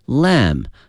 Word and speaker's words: lamb: Lamb，它的拼写呢是 L-A-M-B，哎，它的拼写有一个 B，但是它的发音是没有 B 的。